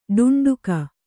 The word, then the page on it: ಟುಂಟುಕ: Kannada